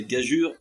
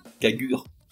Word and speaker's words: gageure: D'après ce que je comprends de cette règle saugrenue, et pour la simplifier, le E entre le G et le U sert à prononcer gageure et non cagure. Comme par exemple dans la phrase Paul mangea des haricots verts. Mangea M-A-N-G-E-A.